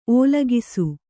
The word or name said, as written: ಓಲಗಿಸು